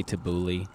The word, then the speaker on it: tabbouleh: How do you pronounce that? – I would say tabbouleh. – Mandy says tabbouleh.